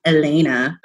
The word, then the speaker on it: Helene: I really liked the scene, the beginning scene between Villanelle and apparently it's Elena, but it's spelled Helene.